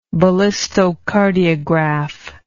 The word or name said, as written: ballistocardiograph